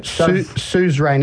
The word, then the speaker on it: suzerain: Suzerain, how do you say that? – Suzerain.